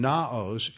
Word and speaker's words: Naos: Hieros, that is spelled H-I-E-R-O-S, H-I-E-R-O-S, and Naos, N-A-O-S.